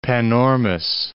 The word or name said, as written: Panormus